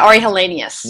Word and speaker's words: Ari Helenius: So you did your MD PhD at Yale, and your mentor there was Ari Helenius. Am I pronouncing that right? – Yeah, Ari Helenius.